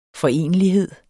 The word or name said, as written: forenelighed